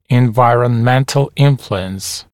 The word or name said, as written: environmental influence